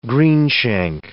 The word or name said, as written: greenshank